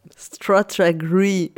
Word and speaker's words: Stratagri: On est bien d'accord qu'il n'y a pas le 2 L E à la fin. C'est GRI, c'est ça Ouais, c'est Stratagri.